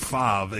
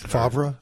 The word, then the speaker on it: Favre: That's F-A-V-R-E. – Yeah, but I say Brett Favre. I just like to say it. – You can't pronounce the R before the V.